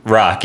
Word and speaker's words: Roch: Yeah, his name's Rock. Yeah, yeah. it's not short for anything. It's spelled R-O-C-H, so a lot of people mispronounce it Roch or Roach or something. But no, it's Rock Talitsky.